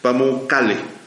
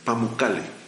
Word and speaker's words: Pamukkale: Eh, se llama la ciudad de Pamu, Pamu ay, a ver si pronuncio bien, Pamukkale, Pamukkale, con doble M.